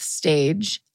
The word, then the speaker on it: stage: And then in parentheses, it says pronounced stage, S A S T A J. So it's stag. Stag. Stag. Stag. Stag. Because that J is yeah. not a G, no.